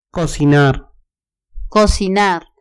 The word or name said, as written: cocinar